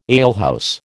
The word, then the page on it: alehouse: American English